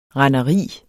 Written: renderi

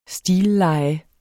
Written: stilleje

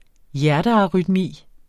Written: hjertearytmi

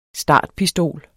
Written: startpistol

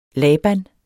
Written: laban

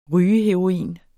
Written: rygeheroin

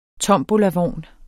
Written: tombolavogn

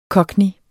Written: Cockney

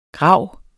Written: GRAVES

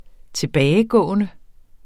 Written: tilbagegående

